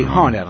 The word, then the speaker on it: Hohner: Supongo que se pronuncia porque sí, Hochner en realidad. – Hochner. – Sí. – No, porque no tiene diéresis.